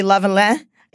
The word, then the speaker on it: Lavalin: So I pronounce it SNC Lavalin. It's a company in Quebec. I'm of French Canadian descent, and my grandmother, who only spoke Canadian French for the first like five years of her life would kill me if I didn't pronounce this properly.